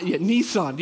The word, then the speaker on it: Nissan: I believe that Smyrna, Murfreesboro, you guys have got, I say Nissen. Okay, is that how you guys say it? I know that sometimes it's pronounced differently. The Nissen factory. Nissan. Yeah, thanks. I was saying it wrong.